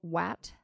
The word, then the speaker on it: WAT: Have you seen the video WAT? Am I saying that correctly? – Yes.